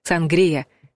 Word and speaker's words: сангрия: Зато есть рекомендация — ставить ударение на предпоследний слог — «сангрия». Даже если чаще вы слышали вариант с ударением на первом слоге.